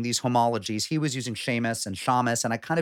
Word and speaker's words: shamus: Same word, shamus, shamish, depending on your pronunciation.